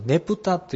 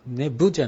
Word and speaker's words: ネプタ: 夏になりますとネプタというネブじゃなくてネプタという発音になるんですけども、